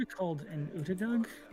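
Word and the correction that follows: otyugh: Odiug. – Odiug.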